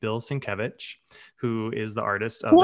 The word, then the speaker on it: Sienkiewicz: Sienkiewicz. Repeat it after me. Sienkiewicz.